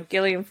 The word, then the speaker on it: Gillian: And I guess so is, you know, Gillian Flynn. I Googled it, by the way. It's a hard G. It's a hard G. You were right.